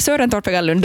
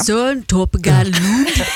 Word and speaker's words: Søren Torpegaard Lund: Ja, Torpegaard, Søren Torpegaard Lund. Du ja. Hade fint danskt uttal där. – Jag tycker det är Søren Torpegaard Lund.